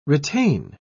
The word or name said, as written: retain